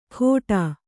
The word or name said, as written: ಖೋಟಾ